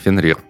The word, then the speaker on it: Фенрир: Э может оказаться правдой, потому что у них же есть Фернир, фер, фер, Фефнир, как он там? – Фенрир. – Фенрир, да, спасибо. – Но, но не Февнир. Фенрир же.